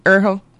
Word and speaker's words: Urho: Urho.